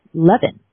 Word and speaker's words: Lewin: developed by German Tavistock operative Kurt Lewin, or pronounced German, Levin, who emigrated to the United States in the 1930s and whose students founded NTL.